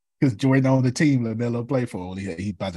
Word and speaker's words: LaMelo: Lamelo, L-A-M-E-L-O.